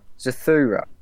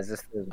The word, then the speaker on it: Zathura: Oh, yeah, that's how you say it. – What were you saying? Zafara. How the hell are you... – Yeah. – Where are you getting the F from? Yeah, Zathura.